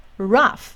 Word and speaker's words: rough: 好，最后在字尾大部分是念辅的音，rough rough rough tough tough tough。